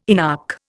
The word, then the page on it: inarch: Indian